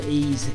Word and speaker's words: Is: You spell it I-S. Pronounced Ease is the title of his EP, and that was a track called Parking.